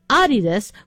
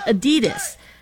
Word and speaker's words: Adidas: Apparently, it's supposed to be pronounced Adidas with the slight emphasis on the first syllable, not Adidas.